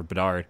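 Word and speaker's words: Bednar: Like, if you don't want to pay the cost for Bednard, I should say, I keep saying his name wrong, for Bedard, talk about Will Crow.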